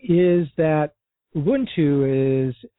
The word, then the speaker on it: Ubuntu: If you listen to how most African words are pronounced, it would just make sense that it would be pronounced Ubuntu.